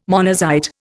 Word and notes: monazite: Indian

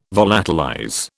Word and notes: volatilize: British English

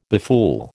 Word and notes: befool: American English

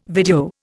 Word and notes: video: Indian

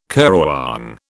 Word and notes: currawong: British English

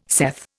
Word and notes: saith: Indian

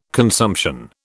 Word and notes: consumption: British English

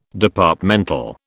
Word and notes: Departmental: British English

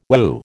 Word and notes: Wu: American English

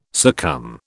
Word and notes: succumb: British English

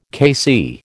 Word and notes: KC: American English

